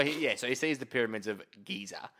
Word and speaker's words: Giza: Sorry, is it pronounced any other way? Can it be? Can like geyser or Giboo? – No, it's what? – It's just Giza. – I think it's Giza. Yeah. – Giboo. No, it's pronounced Giza.